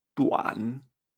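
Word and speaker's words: duǎn: Por ejemplo, tenemos aquí cómo se dice corto en chino, que se, que se dice D-U-A-N en tercer tono. Esto se pronuncia tuan. Tuan, corto. Fíjate como lo diríamos en español, exceptuando que la D es una T suave, ¿verdad?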